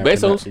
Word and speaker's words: Bezos: Who, Bezos? – You gotta pronounce the Z. – You gotta pronounce the Z. Bezos.